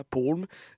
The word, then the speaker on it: Palm: Palm, c'est ou Palm, je pense que ça se prononce plus comme ça, Palm.